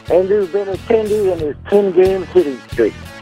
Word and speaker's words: Benintendi: Oh, what a play by Andrew Benintendi. – Got the name right.